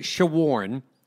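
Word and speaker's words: Shawaryn: But he says it Shawarn, like I'm trying to shorn you about the impending Texas Rangers series, and we'll get to that in short order.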